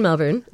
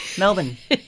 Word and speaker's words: Melbourne: I used to live in Melbourne. And then towards the... You just said it right. You do. Melbourne.